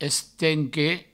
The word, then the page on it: estent que: Provençal